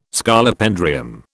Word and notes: Scolopendrium: British English

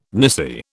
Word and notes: nisi: British English

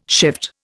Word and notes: shift: Indian

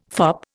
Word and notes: fop: Indian

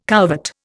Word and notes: couvert: Indian

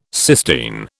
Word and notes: Sistine: British English

